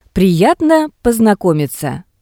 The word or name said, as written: Priyatno posnakomitsya